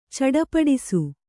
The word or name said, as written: ಚಟಪಟಿಸು